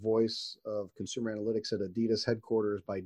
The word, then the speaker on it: Adidas: And so Kai is actually the director of voice and consumer analytics at Adidas, or as properly pronounced, Adidas.